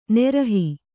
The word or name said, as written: ನಿರೀಹ